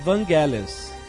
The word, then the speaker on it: Vangelis: É Evangelis? – Não é Evangelis. – Não é, não é do Evangelis. – Ah, cada um pronuncia de um jeito, O, o, o, o Ridley Scott fala Vangelis.